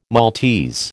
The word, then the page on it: Maltese: American English